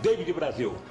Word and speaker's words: David Brasil: Davi Brasil. Uhum. Uhum, não, Davi Brasil. Davi Brasil. 10 pontos. – Eu pronunciei errado. David Brasil.